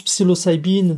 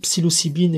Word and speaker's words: psilocybine: Bon, moi je prononce psilocybine, d'autres disent psilocybine, etc., etc. Je vais le prononcer à l'anglaise, comme ça c'est clair pour tout le monde. Psilocybine.